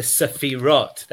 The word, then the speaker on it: Sephiroth: That is the problem of the Sephiroth. That's the way to pronounce it.